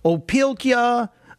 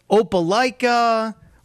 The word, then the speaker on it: Opelka: Look, as a kid, with the last name Opelka, my name was butchered 9 times till Sunday every time I went to a new classroom. Opelka, Opelika, whatever it is.